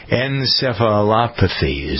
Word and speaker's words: encephalopathy: Encephalopathy is how you pronounce the word.